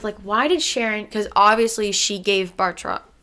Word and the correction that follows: Batroc: I keep saying his name wrong, Batroc, she gave Batroc the supplies.